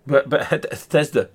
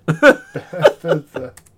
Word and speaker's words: Bethesda: Bethesda. – Bethesda.